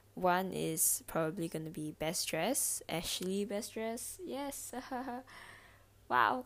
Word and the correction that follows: Best Dressed: I keep pronouncing things weirdly, but Best Dressed.